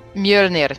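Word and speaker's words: Mjölnir: Mjolnir.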